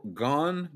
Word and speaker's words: Gane: Cyril Gon? How do you say it? Like, Gane? – I think it's Gon. Cyril Gon. – Gon. Yeah, Cyril Gon. – Yeah, I thought it was Gane at first,